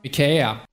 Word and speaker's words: IKEA: I found a clip from their CEO, and this is the proper Swedish pronunciation. – IKEA.